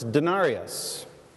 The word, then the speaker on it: denarius: It's denarius.